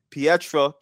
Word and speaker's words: Pietra: So Olga González is the CEO of Pietra Communications. Did I pronounce that right? – You did, yes.